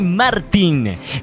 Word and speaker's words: Martín: Estoy hablando de Dani Martín. Bienvenido, Martín. – Con acento en la I.